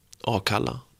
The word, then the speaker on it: Akalla: Akalla. Hur säger man det? Akalla. Ett ord, fem bokstäver, tre stavelser. Akalla. Och där varje A låter precis olika. Låter precis olika som den förra.